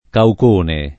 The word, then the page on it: caucone: lingua italiana